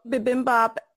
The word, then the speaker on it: bibimbap: So Michelle, bibimbap, am I pronouncing that correctly? – Yes.